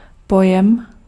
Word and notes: terme: France; [tɛʁm]

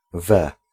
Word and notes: in: /ɪn/; [ɪn]; /n̩/

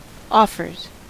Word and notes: offers: American English

